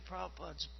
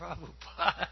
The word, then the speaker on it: Prabhupada: it's a Prabhupada. It's a Prabhupada. That's the British way of pronouncing.